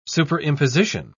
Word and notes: superimposition: British English

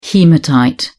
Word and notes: haematite: British English

